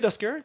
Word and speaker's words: Duskers: Donc, c'est D-U-S-K-E-R-S, Duskers. – Oui, tu le prononces comme il faut.